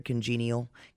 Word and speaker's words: congenial: But she mispronounced the first word, congenial. Give me PC2. – Okay. – So here's Pat Sajak breaking the bad news. – Charlotte, would you read what's up there, please? – Congenital company and clever conversation.